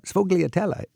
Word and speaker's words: sfogliatella: I know I was walking from Michele's towards the train station, and sfogliatella, something I'm not pronouncing oh, yeah. – it properly. – Oh, yeah, sfogliatella.